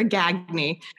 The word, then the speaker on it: Gagne: I like the French version, but we're a Gagne, the Massachusetts Springfield version.